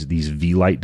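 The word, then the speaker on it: Vielight: And then the other thing that we did, and this was just based on a personal hypothesis of mine, was we have these V-Lite devices. It's spelled V-I-E light.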